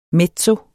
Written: mezzo

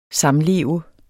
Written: SAMLEVE

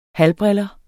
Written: halvbriller

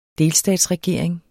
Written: delstatsregering

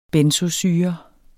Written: benzoesyre

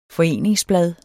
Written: foreningsblad

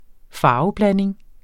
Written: farveblanding

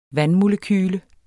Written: vandmolekyle

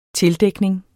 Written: tildækning

